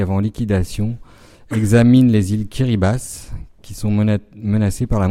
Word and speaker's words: Kiribati: Et ensuite, ce pays, donc les îles Kiribati, ça s'écrit Kiribati, mais ça se prononce Kiribati, ont une particularité, c'est qu'elles sont menacées par la montée des eaux due au changement climatique.